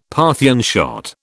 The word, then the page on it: Parthian shot: British English